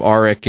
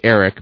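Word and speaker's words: Aric: If Dominic, if you're listening, of course, you remember the whole thing about calling him Arik and when it's actually pronounced Eric.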